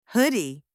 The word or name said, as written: hoodie